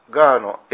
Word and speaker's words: GHA: まあ、そういう世界から離れて、今教会を拠点にして、若い人のですね劇団を作ってですね、ガーという、ガーというのはガは、ごめんなさいのガ、アーのガーの A は、 G はごめんなさいの G、 A はありがとうの A、 H は半分子の H、それで GHA と書いてガーと読むんですね。